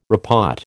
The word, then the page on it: repot: American English